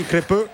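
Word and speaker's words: Crépeau: Beh, le formazioni bisogna leggerle, anche perché c'è un Canada super aggressivo con un 4-4-2, Crepeux in porta con il consiglio della pronuncia di Tommaso Angelini, Crepeux.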